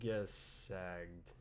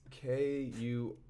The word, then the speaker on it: Kurzgesagt: Kurzikstat? – How do you pronounce it? Kurzgesagt. – If anybody wants to look it up, it's Kurzgesagt.